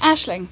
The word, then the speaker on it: Aisling: Am I pronouncing it correctly? – Yes. – Aisling. – Yes. – Oh, hey. – I get all sorts of things like Aisling, Siobhan. But Aisling is the one.